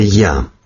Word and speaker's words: Ия: Вот такой интересный псевдоним, который пишется как Ия, но произносится Я. То есть по-русски звучит Я Русская.